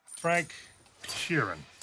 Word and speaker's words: Frank Sheeran: Arre, Frank Sheeran. I saying that right? Yeah, you said right.